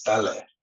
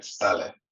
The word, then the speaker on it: Ta Lę: Yeah, it's Tale instead of Tale. Okay. It doesn't, the meaning is not, it has nothing to do with Tale, but yes, Tale is the correct pronunciation.